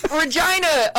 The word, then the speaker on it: Regina: Regina, the city that rhymes with fun.